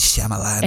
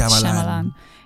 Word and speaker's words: Shyamalan: de como no sé cómo se pronuncia, Chamalayan. – M, Night Shyamalan. – M. Night Shyamalan.